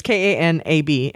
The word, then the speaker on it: Kanab: And they are located in Kanab. How do you pronounce that? – Kanab, Utah. Anybody from Utah, tell us how to pronounce that, right? It's Kanab.